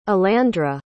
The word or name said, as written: alandra